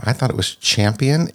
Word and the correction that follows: Campion: It's he said Campion. I think I think he's right because there's no H. There's no. It's just um, C A M P I P I O N. Yeah, Campion.